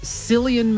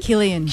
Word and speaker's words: Cillian: We have Cillian Murphy. Is that how you say his name? – Cillian. – Cillian.